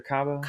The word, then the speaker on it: Kaba: How do you pronounce her name? Miriam Kaba or Kaba? – Kaba.